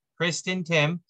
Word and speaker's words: Kristin Timm: It's Kristin Timm.